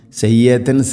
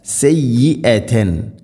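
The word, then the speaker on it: سَيِّئَةً: اخفا بغنا من كسب سيئه واحاطت سيئه സയ്യിആ ഹംസ ഉച്ചാരണം ശ്രദ്ധിക്കണം. സയ്യത്തൻ സയ്യത്തൻ എന്നല്ല സയ്യിഅതൻ.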